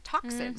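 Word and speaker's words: tocsin: I mean, the pronunciation was capital T-O-C-K, right. – Talk, and then small S-I-N, toxin. So it sounds just like toxin.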